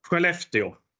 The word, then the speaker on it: Skellefteå: Skellefteå.